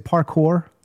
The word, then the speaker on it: parkour: I always say this wrong. Is it parkour? – Yeah.